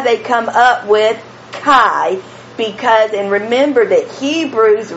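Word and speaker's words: Chi (חי): The CH is pronounced like Bach, so Chi. And then the I, like in high, so Chi.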